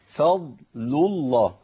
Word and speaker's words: فضل الله: الخطا الثاني، ذلك فضل الله، فضل الله، عندي مشكلة كبيرة جدا في الكلمتين دول، المشكلة مش في الكلمتين، المشكلة في نطق الكلمتين. الفاء مرققة، الضاد مفخمة، اللام مرققة، لام اسم الجلالة مفخمة، يبقى أنا بخرج من ترقيق لتفخيم، من ترقيق لتفخيم، محتاج أعمل إيه؟